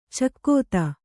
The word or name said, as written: ಚಕೋತ್ತು